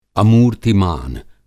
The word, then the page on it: अमूर्त्तिमान: Hindi